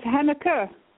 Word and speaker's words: Henneke: It's Henneke.